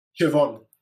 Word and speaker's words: Siobhan: Sioban? S-O-S-I. Huh? – Siobhan. – How do you say it? – Siobhan. – Siobhan or Vaughn? Or is it pronounced as a V? – Siobhan. – With V. Siobhan.